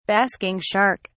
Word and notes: basking shark: British English

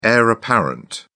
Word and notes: heir apparent: British English

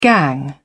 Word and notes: gang: British English

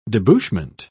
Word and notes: debouchment: British English

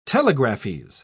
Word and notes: telegraphese: British English

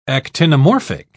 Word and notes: actinomorphic: British English

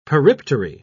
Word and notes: periptery: British English

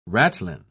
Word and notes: ratline: British English

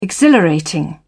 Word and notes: exhilarating: British English

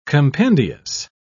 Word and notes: compendious: British English

